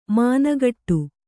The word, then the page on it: ಮಾನಗಟ್ಟು: mānagaṭṭu